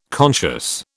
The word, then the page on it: conscious: British English